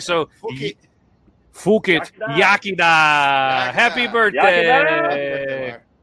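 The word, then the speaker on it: iechyd da: Yakida. – Is that how you say it? Yakida? – Yes. It says it's pronounced, it's sometimes anglicized to Yakida.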